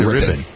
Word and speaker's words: Rypien: Is that how you pronounce it? – Rypien. – Rypien, right? – Yeah.